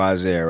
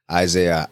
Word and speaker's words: Isaiah: Okay, so my name is Joshua Isaiah, right? It's J-E-S-H-U-A. Isaiah, I-S-A-I-A-H.